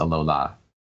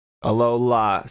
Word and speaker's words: Alola: Like Aloha, which you would say to welcome or say goodbye to somebody in Hawaii, except it's Alola. – Alola.